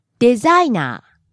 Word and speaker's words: デザイナー: Actually, there are two ways to pronounce this word. – Help me out here. What two ways? – はい。The first one is デザイナー。 – Low to high, then back down to low. – Designer. That's what Akihiro-san said. And the other pronunciation is designer. – And there you stay high. Start low and stay high.